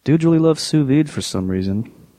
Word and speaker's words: sous vide: And some dude said like, um, "How about you use a, a sous vide?" – Mm-hmm.